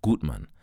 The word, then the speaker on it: Gutmann: The one gracing us with her presence in this episode is none other than Madam US Ambassador Gutmann, or Gutmann, as her name would be pronounced in German.